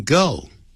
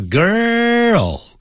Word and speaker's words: girl: 曾经活过一个很美的一个年轻少女你不能这样翻译有一位就直接等于 there was 的意思 there was a beautiful young girl 这 girl 不要念成 go 叫 girl 先 er 舌头往里卷然后再弹起来就像这么样 girl girl girl 啊，